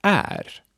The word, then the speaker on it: är: Then we have är, which is a present tense of vara, to be. Är is mostly pronounced like e or ä.